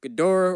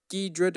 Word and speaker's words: Ghidorah: We got Godzilla, Ghidorah, or Ghidra, depending on how you want to pronounce it, Rodan, Mothra.